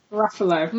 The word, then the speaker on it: Ruffalo: I pretend it's like Gruffalo, like the Gruffalo. So he's Ruffalo.